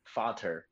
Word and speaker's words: Vater: Vater is how you say it in German versus Vader.